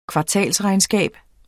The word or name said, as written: kvartalsregnskab